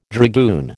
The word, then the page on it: dragoon: American English